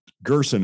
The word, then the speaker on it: Gerson: Gerson. It's just like person except with a G.